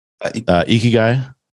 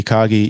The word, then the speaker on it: ikigai: Ikigai. – Yeah. – People pronounce it different ways. It's ikagi, ikigai.